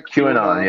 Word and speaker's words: QAnon: QAnon.